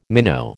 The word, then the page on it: minnow: American English